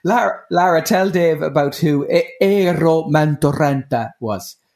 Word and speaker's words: Eero Mäntyranta: Eero Mäntyranta. – Eero Mäntyranta. – he's just confirming it there for you. – Eero Mäntyranta.